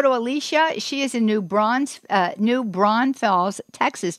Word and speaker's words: New Braunfels: It's New Braunfels, yeah.